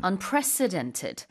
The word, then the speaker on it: unprecedented: Unprecedented 가운데 C가 약간 강하게 발음이 되어야 돼요. Unprecedented 하면 안 되고 그러면은 President처럼 들리잖아요. 대통령 아니고요. Unprecedented.